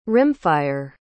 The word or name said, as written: rimfire